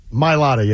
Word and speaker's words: Mailata: Mailata? – Yeah. – Mailata, yeah.